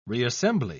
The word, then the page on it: reassembly: British English